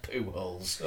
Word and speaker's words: Pujols: It's spelled P O Pujoles, but it's pronounced Pujols.